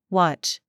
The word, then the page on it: CHURCH: American English